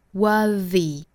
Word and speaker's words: worthy: 那再提一嘴哈，就是这个词其实如果后面加了一个字母 y 的话，变成那个形容词之后呢，它的读法就变成了浊辅音的 z 的发音了，那应该读 worthy, worthy。